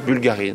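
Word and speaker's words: Bvlgari: Ouais, en fait, ça s'écrit sans V. C'est Bulgari, normalement. Mais là, ils ont rajouté un petit V.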